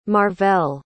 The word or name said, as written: marvell